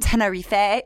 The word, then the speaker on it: Tenerife: Like, I'll be like, well, I want to go to Tenerife. She's like, oh, you mean Tenerife?